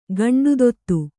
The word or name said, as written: ಗಂಡುದೊತ್ತು